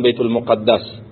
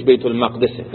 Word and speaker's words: بیت المقدس: در بیت المقدس، بازم در زبان فارسی میگن بیت المقدس ولی تلفظ صحیحش بیت المقدسه، در فلسطین، مسجدی که معروف و مشهور است، مسجد الاقصا.